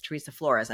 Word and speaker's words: Teresa Flores: So that's why it's interesting we're using Teresa Wilson, because I only know her online as Teresa Flores.